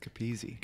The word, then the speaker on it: Capizzi: You said my name wrong. It's Capizzi.